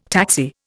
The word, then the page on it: taxi: Indian